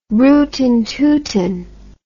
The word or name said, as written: rootin tootin